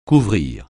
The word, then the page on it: couvrir: French